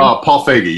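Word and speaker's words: Paul Feig: I don't need Ghostbusters, the director's cut, the three-hour cut that, you know, is it Feig or Feig? How do you say it? Is it Paul Feige? How do you say his name? – Paul Feige, yeah.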